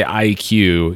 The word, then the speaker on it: Eye-Kyu: Uh, and and by the way, when we say Eye-Kyu, he spells his name E Y E dash K Y U.